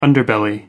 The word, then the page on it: underbelly: American English